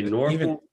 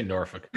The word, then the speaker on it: Norfolk: You can say Norfolk, Norfolk, right? – Yeah, I say Norfolk. – Norfolk. – But yeah, like the real locals say Norfolk. And I've never been able to do that.